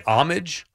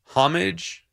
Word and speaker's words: homage: I think it's homage. – Right, that's what I think know. So, too. The H the actual, is silent.